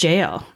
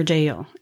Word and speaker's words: Jael: It's Jael, or Jael. It's J-A-E-L is how it's spelled.